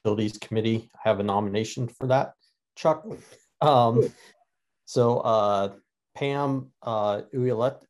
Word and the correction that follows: Ouellette: can you help me with the pronunciation, Ron, or last name? You're on. – Oh, mute. You're muted, Ron. Unmute. – Sorry about that. Pam Ouellette. – Ouellette, yes. So I talked to her today.